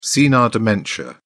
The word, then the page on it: senile dementia: British English